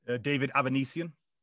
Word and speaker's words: David Avanesian: How do you pronounce the other guy's name? – David Avanesian.